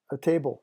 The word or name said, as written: a table